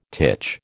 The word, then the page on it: titch: American English